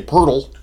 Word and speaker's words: Poeltl: Yeah, say Poeltl.